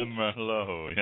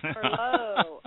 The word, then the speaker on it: Merlot: Merlot, not Merlot, right? – Merlot, yes. – Merlot. Okay, the T is silent.